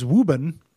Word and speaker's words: Woburn: Folks here in Mass, especially in the eastern half of the state, have a lot of idiosyncratic pronunciations. Quincy is Quincy, Woburn is Woburn, Peabody is Peabody.